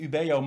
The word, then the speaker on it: Ubeo: Uh, Ubio, Ubeo, ehm, eh, Ubeo mag je gewoon in het Nederlands uitspreken. Uh, Surf wat ehm, oorspronkelijk ook vanuit een Engelse organisatie is ontstaan.